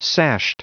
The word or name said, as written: sashed